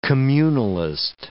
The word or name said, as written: communalist